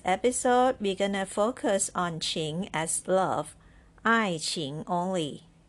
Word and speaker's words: 情: Qing, the pinyin is Q-I-N-G with the second tone put above I. Qing is pronounced this way. Qing, qing, qing qing, second tone, qing.